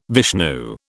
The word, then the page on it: Vishnu: British English